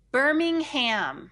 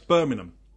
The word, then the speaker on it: Birmingham: Birmingham. – That's quite close. Birmingham. So you don't put the emphasis on the ham.